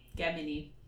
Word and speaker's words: Gemini: Really, it should be a hard G. Gemini. – Gemini. Okay. Well, still, I mean, so they're halfway right? – I think it should be. I think it should be a hard G. Yeah.